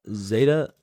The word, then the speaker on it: Zetta: And this is from, it's spelled Z-E-T-T-A. And is that Zeta? – Zeta. – Zeta? – Zeta. Zeta. – Zeta. I'm going to say, I when I initially read it, I thought it was Zeta, but then I changed it to Zeta.